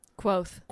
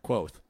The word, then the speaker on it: Kvothe: I've settled on Quoth. – Quoth. – Quoth. It's spelled K V O T H E. – Quoth. – But it's written, spelt, it's written, pronounced similar to Q U O T H E.